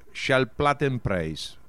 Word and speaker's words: Schallplattenpreis: Tendría que decir con más mesura que el nombre es Schallplattenpreis.